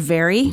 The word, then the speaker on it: vary: I love this word, very. – Very. – It's the same pronunciation as V-E-R-Y. – Mm, right. – But it's with an A, and it means to just be different.